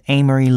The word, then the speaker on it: Amory: My name is Amory Lee. – Amory? That's an unusual name. How do you spell that? – A-M-O-R-Y.